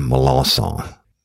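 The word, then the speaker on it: Melançon: But Roy Allen Melanson and that's a good, strong Cajun name.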